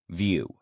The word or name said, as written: view